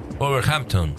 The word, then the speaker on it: Wolverhampton: Wolverhampton.